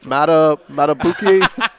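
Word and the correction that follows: Madubuike: Justin Matabike. – Matabike, okay.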